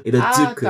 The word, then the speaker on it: Irozuku: Não, é com Z, é porque esse Z ele se pronuncia tipo Z. Irozuku.